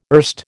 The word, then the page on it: erst: American English